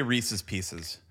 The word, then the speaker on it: Reese's Pieces: Now, how do you pronounce this product? – Okay, I say Reese's Pieces. Hold on. – Me too.